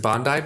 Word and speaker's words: Bondi: That, I mean, Bondi Beach or Bondi, is it, do you pronounce it Bondi Beach? – Bondi.